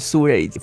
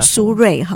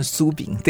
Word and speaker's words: 苏芮: 呃，我想要分享有点年代感的歌曲啊，就这是苏芮奉献。 – 好，苏芮哈，对对，苏芮对，不要念成苏炳。